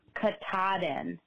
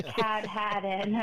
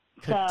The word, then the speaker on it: Katahdin: the mountain where the Appalachian Trail begins in Maine is pronounced Katahdin, not Cat-Hadden, or I was laughing as you guys tried to pronounce it on the show. It's Katahdin with the emphasis on the T-A-H in the middle.